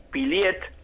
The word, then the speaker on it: Pillet: El Facebook, mi nombre es Pablo y el apellido se escribe con L, es Pillet, P-I-L-L-E-T, en Facebook.